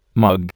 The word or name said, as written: mug